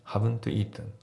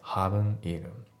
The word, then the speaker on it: haven't eaten: そしたら、まあ、あと発音なんだけど、 haven't の t がね、まあ、ちょっと抜けてるような感じですね。 haven't eaten だね。 haven't eaten じゃなくて haven't eaten。